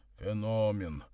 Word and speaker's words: Феномен: Феномен!» — поправил Никодима Горький, поставив ударение на второй слог, провел рукой по рыжим усам и улыбнулся собственным мыслям.